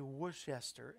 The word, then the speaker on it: Worcester: Worcester, and it looks like that it's the same spelling W O R C E S T E R, and you say you want to say Worcester, and it's that's not it's pronounced Worcester.